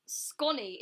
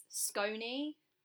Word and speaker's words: scone: Is, it's not sconny, it's scony, like stone, scone.